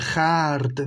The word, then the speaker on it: خرد: این خرد هم که گفتیم همون خرد هست دیگه که به ضرورت قافیه اینطور تلفظ کرد